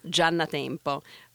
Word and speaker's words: Giannatempo: Okay, so the Italian way is Laura Giannatempo. But nobody says it this way.